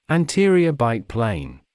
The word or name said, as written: anterior bite plane